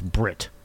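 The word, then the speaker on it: Bret: No, that's Brent. – That's Brett. – No, it's Brit. – Fuck. – It threw me off forever that in New Zealandese they pronounce it as Brit.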